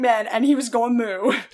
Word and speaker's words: Mooed: So it's spelled like mood, like... – Like mood, like a cow does, yeah. – Not just so, like big mood, but like mood, like a... – M-O-O-E-D.